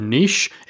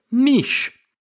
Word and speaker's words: niche: If you are from the UK, then typically that is the correct pronunciation. The word niche is taken from French, and that's the original French pronunciation of the word, niche. But if you're joining us from over in the States, or indeed I know there's several other areas in the world that pronounce it differently, you will say niche.